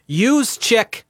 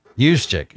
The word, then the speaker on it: Juszczyk: Is it Jukizik? How do you say that? – Well, you asked me this same question 23 hours ago. How to pronounce this same name? Juszczyk. – Juszczyk.